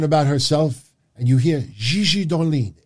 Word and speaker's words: Gigi Dolin: I said her name should be pronounced not Gigi Dolan. It should have been Gigi Dolin.